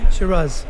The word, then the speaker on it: Shiraz: Shiraz. Did I say it wrong? – Shiraz. – Shiraz. I said it right.